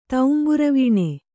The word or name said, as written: ತೌಂಬುರವೀಣೆ